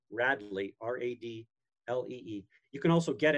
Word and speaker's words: Radlee: Is it Radley? – It's called Radley, and it's spelled R-A-D-L-E-E.